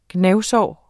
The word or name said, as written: gnavsår